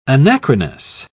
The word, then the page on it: anachronous: British English